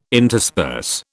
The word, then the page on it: intersperse: British English